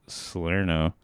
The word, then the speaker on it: Salerno: Salerno. I can't say his name for some reason. – You got it.